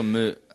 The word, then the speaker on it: Lemieux: Mario Lemieux.